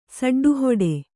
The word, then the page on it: ಸೆಡ್ಡುಹೊಡೆ: seḍḍuhoḍe